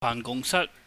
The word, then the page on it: baahngungsat: cantonese